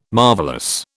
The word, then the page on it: marvellous: British English